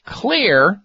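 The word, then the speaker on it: Xlear: Look for, ask for, Clear, spelled with an X, X-L-E-A-R, Nasal Sprays.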